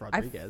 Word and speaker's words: Rodriguez: People would think that Michelle Rodrigo, she is more... – Isn't that Rodriguez? – Rodriguez? Oh, I'm sorry. Am I saying it wrong? – I'm pretty sure it's Rodriguez.